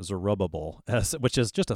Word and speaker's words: Zerubbabel: Zerubbabel is usually how I say it.